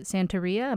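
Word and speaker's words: Santería: One, having been raised in the Santeria, am I saying that right? – Mm-hmm.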